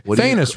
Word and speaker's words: Thanos: Thanos rhymes with anus.